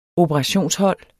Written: operationshold

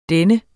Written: dennes

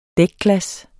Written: dækglas

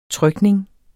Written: trykning

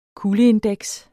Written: kuldeindeks

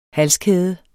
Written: halskæde

